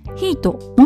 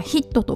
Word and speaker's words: HIIT: ヒートもしくはヒットと読むそうです HIIT